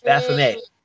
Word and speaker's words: Baphomet: It's Baphomet. Everybody keeps saying Baphomet, Baphomet. It's Baphomet. It's more which would be more like the French pronunciation with a silent T, yeah.